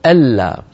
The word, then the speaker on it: ألا: النون الساكنة تدغم في اللام فيصير النطق ألا.